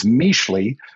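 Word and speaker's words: Mixtli: It's Mixley, which in the Nahuatl language means cloud.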